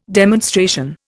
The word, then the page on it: demonstration: Indian